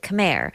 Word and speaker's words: Khmer: And I know we typically say Khmer, but Yoon says it's actually pronounced Khmer.